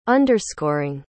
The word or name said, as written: underscoring